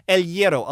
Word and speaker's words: El Hierro: El Hierro. I'll spell it. E-L. – Yeah. – And the one word, L. And then Hierro. H-I-E-R-R-O. – All right. – El Hierro is what it looks like.